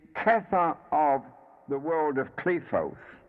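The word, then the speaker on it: Qliphoth: But as I explained before, the P is a pay, and pay has a dual pronunciation depending upon where the dot or the doggish is placed. The preferable pronunciation is Klefoth. There is a set of Klefoth referred to every Sephira.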